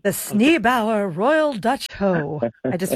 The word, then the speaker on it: Sneeboer: Sneebower. So it's spelled S-N-E-E-B-O-E-R, Sneebower, the Royal Dutch Hoe.